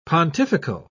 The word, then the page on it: pontifical: British English